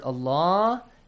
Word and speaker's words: Allah: The letter lam in the word Allah has to be pronounced with a light sound or heavy sound, Hussein? Ali Nasir? – Heavy. – Heavy sound. Because there is a dhamma sound before.